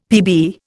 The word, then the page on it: Pb: Indian